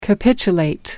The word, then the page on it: capitulate: American English